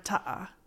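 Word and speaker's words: ta7ah: Like, so how do you pronounce the seven? – It's just a space. – Okay. – Right. So, for example, we have this word ta'a. That's like grandma, right? It's T-A-7-A-H.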